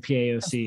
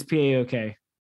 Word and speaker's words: PAOK: Yeah, I said C instead of K. It's PAOK.